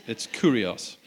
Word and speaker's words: kurios: Nigel can tell you how to pronounce that properly. I make it sound like an aftershave, but it's kurios, which means master.